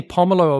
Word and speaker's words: pomelo: Did you just say pomelo or pomelo? – I said pomelo. – Oh, you're right. Okay.